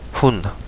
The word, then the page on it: huna: kapingamarangi